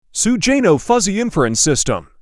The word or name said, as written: sugeno fuzzy inference system